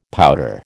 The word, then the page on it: pouter: American English